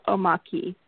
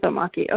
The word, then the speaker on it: omake: Omake or omaki? – Ki. – Ki, omaki. Okay. – Okay. – It's omaki.